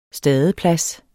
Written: stadeplads

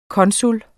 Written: konsul

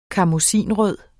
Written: karmoisinrød

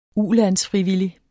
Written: ulandsfrivillig

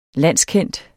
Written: landskendt

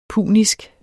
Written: punisk

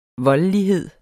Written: voldelighed

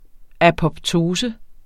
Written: apoptose

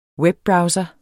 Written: webbrowser

